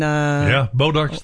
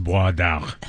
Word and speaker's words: Bois d'Arc: There is A-E-U-I-U. – B-A-U-S. – B-O-I-S-D apostrophe A-R-C. In French, it's pronounced Bois d'Arc. – Whatever. – We call it Bodark because we're from Texas.